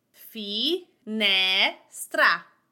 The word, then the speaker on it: finestra: And my accent goes on that ne. Fi, ne, stra. Finestra, window. Now,